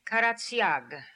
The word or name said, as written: Karatsiag